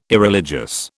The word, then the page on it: Irreligious: British English